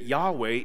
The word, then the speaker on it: Yahweh: Scholars today believe that Yahweh is the most likely pronunciation for these four consonants that we have.